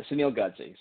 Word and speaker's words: Sunil Godse: Sunil Godsey.